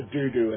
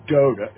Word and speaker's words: dodo: It's not a doo-doo. It's a dodo. It's a dodo bird. – Oh, a dodo. What's D-O-D-O? – Yeah, I know, D-O-D-O, but I never thought you would pronounce it that way.